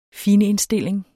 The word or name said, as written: finindstilling